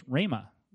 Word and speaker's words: rhema: Another one is rhema, R-H-E-M-A is the way we would spell it if we transliterate it.